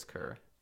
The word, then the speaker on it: Kerr: I think it's spelled Kerr. People pronounce it Kerr.